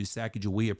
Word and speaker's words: Sacagawea: In Hidatsu, Sacagawea, pronounced with a hard G, translates into bird woman.